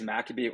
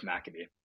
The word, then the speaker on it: Maccabi: It can go both ways, Maccabi or Maccabi. – Okay. – I normally go with Maccabi.